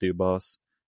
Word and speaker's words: Dubas: The Penguins have always been a name in the mix ever since Kyle Dubas has, I think his last name's pronounced Dubas. Dubas. I'm going to say Dubas for this episode.